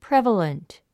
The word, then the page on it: prevalent: prév ə lənt; プレバレント